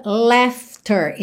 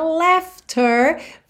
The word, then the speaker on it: laughter: The laughter, esse GH do meio da palavra laughter. Você, se tem acompanhado a série Aprenda Inglês com Música, já sabe que tem som de F, ok? Então, laughter, sem mistério. And the pain, and even share the tears.